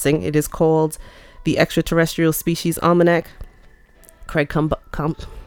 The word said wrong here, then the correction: Campobasso: Actually, I've been saying his name wrong. Combasso. C-A-M-P-O-B-A-S-S-O.